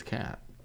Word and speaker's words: Kat Dennings: Kat Dennings. – Yes, Kate Dennings. – I thought it was Kat.